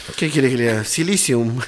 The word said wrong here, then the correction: psyllium: No, Silium.